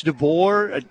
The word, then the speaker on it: DeBoer: If Alabama – now, am I the only person – is it Caleb DeBoer? Is that how you say it? – Yeah.